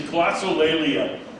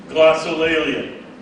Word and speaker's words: glossolalia: The technical term for the gift in Greek is glossolalia. You want me to spell that? For those of you who are taking notes, glossolalia. G-L-O-S-S-O. L-A-L-I-A.